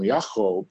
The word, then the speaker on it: Yaakov: And at that point, God will change his name from Yaakov, which is how you pronounce his name in Hebrew, to Israel.